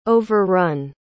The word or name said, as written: overrun